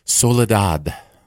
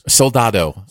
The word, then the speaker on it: Soldado: And, of course, we'll review Sicario 2, Day of the Soledad. – As Soldado, it I thought it was. – Soldado.